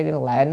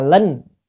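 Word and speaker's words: linh (令): Mà trong khi lệnh á, âm chữ đó cùng một chữ Hán, nếu mà đọc là lệnh thì là mệnh lệnh, ra lệnh. Còn nếu đọc là linh là khiến cho.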